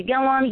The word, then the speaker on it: Gilliam: And then, of course, for any of you people out there that are new, again, my name is Amanda Gilliam. You can follow me on Facebook. It's Amanda Gilliam. It's spelled like William, but with a G instead of the W.